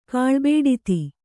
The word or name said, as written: ಕಾಳ್ಬೇಡಿತಿ